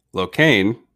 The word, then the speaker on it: Locane: Locane rhymes with cocaine.